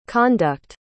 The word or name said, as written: conduct